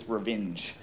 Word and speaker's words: Rovinj: Rovinj. – So we call this Rovinj. – Okay. – Right. Almost like revenge.